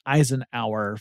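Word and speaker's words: Izenour: In 1955, a fellow named George Charles Eisenhower, that's I-Z-E-N-O-U-R, and it's pronounced Eisenhower from what I understand.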